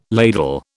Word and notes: ladle: British English

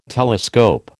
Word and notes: telescope: American English

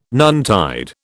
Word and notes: noontide: British English

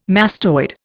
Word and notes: mastoid: Indian

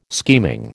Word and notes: scheming: American English

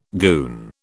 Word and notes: goon: British English

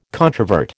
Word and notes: controvert: American English

